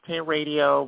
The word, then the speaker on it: Hair Radio: Now, again, it's pronounced Hay Radio, but it only has one R in the middle.